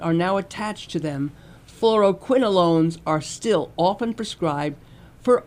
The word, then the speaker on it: fluoroquinolones: He says, and he sums it up by saying, fluoroquinolones, did I say that correctly? – You sounded good.